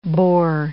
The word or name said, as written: Boer